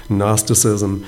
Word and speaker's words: Gnosticism: And there's another error that was creeping into the Colossian church, and that one was something that would become known as Gnosticism, spelled with a silent G, Gnosticism.